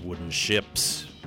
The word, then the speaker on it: Wooden Shjips: That was San Francisco's Wooden Ships with Other Stars. And the Ships, well, spell that with a J in there, S-H-J-I-P-S.